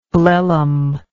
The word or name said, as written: blellum